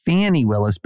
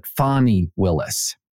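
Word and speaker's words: Fani Willis: So the actual correction is, as a number of listeners sent me, the correction pronunciation of the district attorney for Fulton County, Georgia, is not Fannie Willis, but Fonnie Willis. So it is not Fannie, it is Fonnie.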